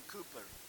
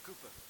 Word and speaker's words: Cowper: hymn by William Cooper. It's spelled Cowper, but it's Cooper.